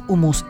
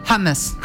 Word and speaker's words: hummus: Y tengo un amigo que me dijo que no es hummus, es hummus. – Hummus. – Que se dice así en armenio.